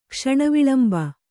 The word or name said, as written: ಕ್ಷಣವಿಳಂಬ